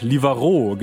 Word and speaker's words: Livarot: Livarot, genau.